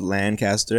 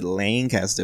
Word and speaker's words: Lancaster: Last night I was in I thought it was called Lancaster. It's outside of Columbus, but they said Lancaster. It was some weird, crazy pronunciation. Some pronunciation I was not expecting.